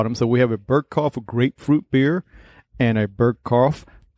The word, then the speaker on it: Burgkopf: We're doing a Burgkopf, if I said that correctly. – Yep. Burgkopf.